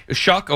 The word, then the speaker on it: Shough: Cam Ward, Shedeur Sanders, Tyler Shough, is that how you say his name? – Shuck, okay, there you go.